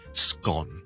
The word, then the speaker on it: scone: 通常好多人叫佢正确嘅发音咧，其实系 scone。好似 S 音之後，我哋廣東話好乾或者好濕嗰個乾咁樣，就 scone。